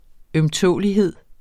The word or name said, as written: ømtålelighed